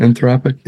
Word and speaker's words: Anthropic: Anthropic.